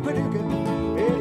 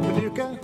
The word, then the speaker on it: Paducah: Well, Paducah, Paducah, if you want it, you can rhyme it with bazooka. But you can't poo-poo Paducah, it's another word for paradise. Well, Paducah, Paducah, just a pretty little city in Kentucky.